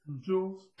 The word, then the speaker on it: joules: And then she says, you know, when you got the 200 joules, how to pronounce Jules, or Joel's? – Jules.